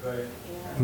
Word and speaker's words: Aramaic: Aramaic.